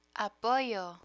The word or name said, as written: apoyo